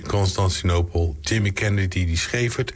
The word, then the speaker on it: Constantinopel: Werd het nummer geschreven, Constantinopel. Zeg ik het goed? Ja, ik zeg het helemaal goed.